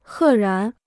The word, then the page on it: 赫然: hè rán